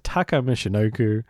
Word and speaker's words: Taka Michinoku: But to give it the correct pronunciation as well, at least I hope it is, Taka Michinoku and the great Sasuke.